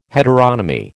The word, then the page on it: heteronomy: American English